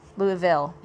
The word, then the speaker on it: Louisville: Am I saying that right? Louisville? Louisville, yeah.